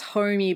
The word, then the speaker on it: Hommey: It's a company called Homey, H-O-M-M-E-Y. – Ooh, it's actually pronounced Homey, but I told them when they were launching that everyone's going to say Homey and they do.